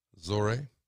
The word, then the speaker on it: Zohreh: Zohreh. Zohreh, that's how you pronounce it.